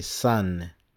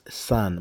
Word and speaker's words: son: Les Américains disent son, son. Mais il ne faut pas confondre avec le soleil parce que le soleil aussi, on prononce son. Mais quand on écrit, c'est avec U, mais quand on prononce, c'est San. Mais le son, le fils, c'est avec O, son. Mais les Américains prononcent San, my son.